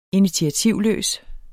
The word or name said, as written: initiativløs